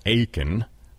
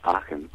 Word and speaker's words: Aachen: I'm calling it Achen, like the American Idol guy, but I think it's pronounced Achen, perhaps. – Well, it's actually the name of a city in Germany, pronounced Aachen.